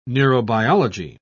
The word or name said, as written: neurobiology